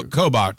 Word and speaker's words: Kobach: Is it, how do you say that? Kobach? Or Kobach. – Kobach. Okay, yeah.